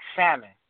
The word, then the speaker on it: salmon: You know, like you have a word like salmon. Well, it's not salmon because the L is silent.